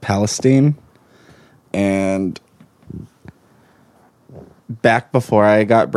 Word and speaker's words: Palestine: But it's pronounced so Palestine.